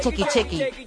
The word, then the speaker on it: Shaki Shaki: Sí, se escribe Shaki Shaki, pero dice Chequi Chequi.